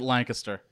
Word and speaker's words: Lancaster: We pronounce it Lancaster. You got to say it like you're sneezing.